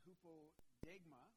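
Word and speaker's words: hupodegma (ὑπόδειγμα): And now, interestingly enough, this example here, Paul uses the gospel writer John uses a compound word, and it's pronounced hupodegma, and it means, hupo, so is a preposition, which means under, okay?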